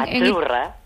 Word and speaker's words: Azzurra: Azzurra. – Azzurra. Mira, ¿ves? – Azzurra. Es que... Azzurra. Sí, como porque pizza. La Z no es la Z como decimos en catalán tan sonora, no. Es azzurra.